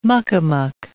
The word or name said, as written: muck-a-muck